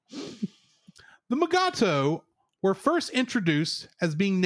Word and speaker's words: Mugato: This creature was named Gumato in the script, but DeForest Kelly, who plays Dr. McCoy, could not pronounce the word Gumato, so it was changed to Mugato, which Kelly and William Shatner proceeded to pronounce Mugatu.